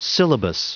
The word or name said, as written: syllabus